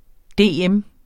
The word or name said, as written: dm